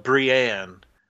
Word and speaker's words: Brianne: Yes, this one is about a woman named, it's spelled Brianne, but they call her Brian throughout.